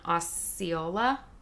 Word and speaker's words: Osceola: It's so large that Disney splits into two different counties, Orange and Osceola.